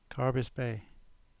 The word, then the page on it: Carbis Bay: English